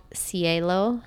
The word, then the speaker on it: Xielo: One is called Cielo, spelled with an X, so X-I-E-L-O, and one is called Neek, N-E-E-K.